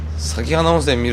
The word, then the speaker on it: 咲花温泉: 美容温泉、美肌の湯って言われてまして、川の方から咲き花温泉見るっていうのが不思議な感じだね。 – 咲く花と書いて咲き花温泉っていうんですけどね。